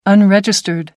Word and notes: unregistered: American English

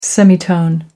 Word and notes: semitone: American English

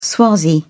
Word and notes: swazi: British English

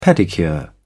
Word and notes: pedicure: British English

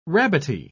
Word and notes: rabbity: British English

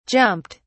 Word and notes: jumped: British English

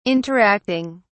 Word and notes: interacting: British English

